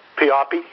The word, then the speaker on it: Pioppi: Pioppi. – Tony Pioppi, Anthony Pioppi. Okay, it's P-I-O-P-P-I.